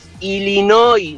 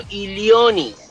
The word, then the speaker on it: Illinois: Es Illinois, no Illionis.